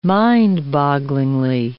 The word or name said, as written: mind-bogglingly